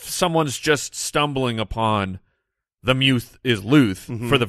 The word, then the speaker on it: Muth: It's pronounced Muth. Muth, but it's spelled M-U-T-H, which you would read Muth. – Yes. – Right? But his name dictates the M-U-T-H because...